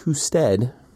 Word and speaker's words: Husted: Not Husted. – It's Husted. Called, no, I think it's pronounced Husted. – It's Husted. He's the Ohio Secretary of State, and he pronounces his family name Husted.